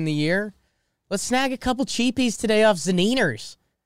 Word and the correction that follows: Zunino: Zaniner? No, Mike Zunino.